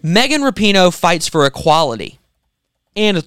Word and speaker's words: Rapinoe: Better say her name right. – So it's Megan Rapinoe. – Rapinoe.